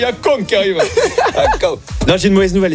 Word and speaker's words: Akon: Oui, il faut le prononcer aussi à la française, je veux dire. Oui. – Vit dans un pays de droit et de valeur. Akon que ça se prononce et c'est ça, Akon.